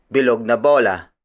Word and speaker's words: bilog na bola: For example, bilog na bola. Bilog na bola. Bilog na bola. Bilog na bola is spelled as B-I-L-O-G space N-A space B-O-L-A.